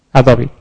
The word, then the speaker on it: Adobe: Is that how I pronounce Adobe? Adobe, yeah. Sorry.